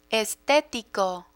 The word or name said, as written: estetico